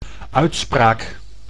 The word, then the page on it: uitspraak: Dutch